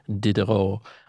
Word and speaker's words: Diderot: Well, you don't pronounce the T. That's usually a sign. – Diderot.